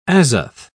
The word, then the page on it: azoth: British English